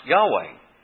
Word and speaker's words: Yahweh: which is probably pronounced Yahweh. What happened is, rabbis wanted to build a fence around the law.